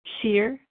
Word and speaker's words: Shir: Shear F. – Shear. How do you spell that? – S-H-I-R.